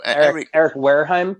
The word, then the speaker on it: Wareheim: Eric Wareheim.